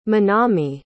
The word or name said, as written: Manami